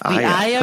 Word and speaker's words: AYA: I hope I'm pronouncing it right. The AYA Research Institute. Is that correct? – That is perfect. Yes. AYA perfect.